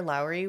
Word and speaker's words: Lauri: Though her mother, Britta, was caring, her father, Lowry, was incredibly strict, and that's spelled L-A-U-R-I. They said people in the neighborhood called him Larry, but that's not his name, so I'm going to call Lowry? – Him. I think so. My pronunciation might not be perfect.